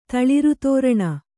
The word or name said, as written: ತಳಿರ್ದೋರಣ